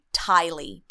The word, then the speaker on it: Tylee: Tylee. – T-Y-L-E-E.